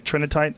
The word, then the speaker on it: Trinitite: Trinitite, that's how you say it? – Yeah.